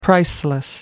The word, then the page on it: priceless: American English